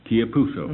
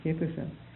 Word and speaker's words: Chiapusso: C, how do you pronounce this? Spell that. – C-H-I-A-P-U-S-S-O. – Chiapuso. – Mm-hmm. Chiapuso. John Chiapuso.